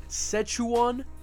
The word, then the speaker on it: Szechuan: Szechuan sauce. That's how you say it.